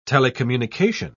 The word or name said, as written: telecommunication